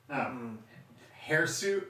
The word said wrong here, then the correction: hirsute: really? – Hirsute. H-I-R-S-U-T-E. Hirsute. – Oh, okay. It's just spelled... It's pronounced adjective. – Like H-A-I-R-S-U-I-T. – Kind of.